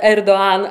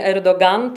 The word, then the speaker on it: Erdoğan: Ten, który rządzi Turcją aktualnie, czyli prezydent Recep Tayyip Erdoğan, ja od razu powiem, ponieważ ktoś mi zarzucił, że źle wymawiam jego nazwisko. Otóż nazwisko wymawia się naprawdę Erdoğan, a nie Erdoğan. To jest taka literka w tureckim alfabecie, tak zwane Yumuşakge i ono jest takie niewymawialne, po prostu się je połyka. Więc jest to prezydent Erdoğan, a nie tak jak często słyszymy Erdoğan.